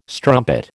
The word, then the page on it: strumpet: American English